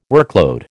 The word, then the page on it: workload: American English